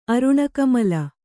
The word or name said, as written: ಅರುಣಕಮಲ